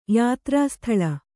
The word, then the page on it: ಯಾತ್ರಾಸ್ಥಳ: Kannada